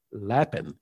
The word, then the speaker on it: Lapin: I read a book some time ago, a few years back, by a Jewish rabbi, Daniel Lapin, L-A-P-I-N, I think is the way you pronounce that.